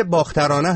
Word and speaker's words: باخترانه: بحث باختره و ترانه، مثل فردا که تهش با آ تموم میشه و آهنگ که با آ شروع میشه، باخترانه هم این گونه هست.